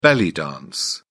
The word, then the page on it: belly dance: British English